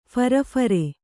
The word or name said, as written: ಫರಫರೆ